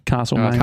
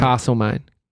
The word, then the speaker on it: Castlemaine: Is it Castlemaine or Castlemaine? – Castlemaine. – Castlemaine.